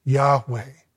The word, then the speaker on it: Yahweh: And this is, it's a long story, but it's where we get what we think is the right way to pronounce the actual name of the Lord, Yahweh. That's where this comes from. Y-H, W-H are the consonants.